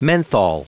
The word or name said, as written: menthol